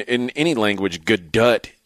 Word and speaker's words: Gadot: Actually, yeah, she leaves the T on there. It's Gadot. – Oh, does she? – Yeah. – Oh, well. – Right. Actually, Gadot, I believe, is the actual pronunciation.